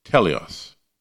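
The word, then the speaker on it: teleos (τέλειος): In Greek, this word is pronounced teleos.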